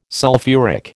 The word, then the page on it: sulphuric: American English